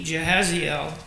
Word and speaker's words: Jahaziel: And he, Jahaziel, pronounced as I did,